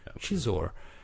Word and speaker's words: Xizor: Och sen kommer Darth Vader som äntligen har fått reda på att eh, Shizor, uttalar man det så? Xiz, Xizor? Shizor. Eh, att han är en eh, skum fisk.